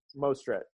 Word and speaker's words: Mostert: Merced, is that how you pronounce his name? Merced or Morset? – Mostert, okay.